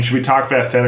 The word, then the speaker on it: Fast X: I think it's pronounced 10. – Yeah. Well, it's in Rome. It is. – When in Rome pronounce it 10. – Yeah. I had Fast 10 as well.